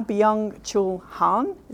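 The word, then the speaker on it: Byung-Chul Han: And this is my new favourite guy and I apologise for the, me pronouncing it now, Byung Chul Han. Is that right? Anyone help me with that? You're right. Is that good? – Yeah, great. Really good.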